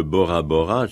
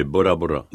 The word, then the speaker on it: Bora Bora: Oui, au lieu de Bora Bora, c'est Bora Bora. – Bora Bora. – Ouais. Et en réalité, à la tahitienne, ce serait Bora Bora.